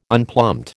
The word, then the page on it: unplumbed: American English